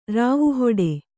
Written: ರಾವುಹಿಡಿ

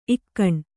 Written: ಈಗಿನ

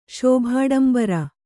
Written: ಶೋಭಾಡಂಬರ

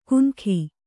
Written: ಕುನಖಿ

